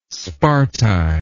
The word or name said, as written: sparti